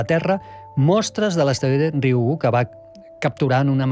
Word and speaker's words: Ryugu: És molt fàcil, com una I, Ryugu.